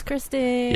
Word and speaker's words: Kristen: Yo, it's Kristen.